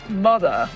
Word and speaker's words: Mutha: Some Secret Side before that, called Versailles from Hidden Secrets, an album that came out in 1983 on the New Jersey Mother Records, Mother, M-U-T-H-A, record label. I like that pronunciation too, Mother Records.